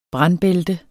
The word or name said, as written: brandbælte